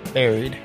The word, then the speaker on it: buried: Buried. Anyway, so. – Is that how you pronounce it? Is it buried or buried? – Buried. – Buried? – Like Barry. Like Barry Allen.